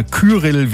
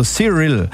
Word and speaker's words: Cyril: Und was habe ich da schon für Ausspracheversuche gehört? Cyril oder Kyrill wie der Sturm, aber es spricht sich wie Cyril, also Cyril.